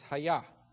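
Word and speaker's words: haya (היה): If you want the Hebrew pronunciation, it's haya, to be.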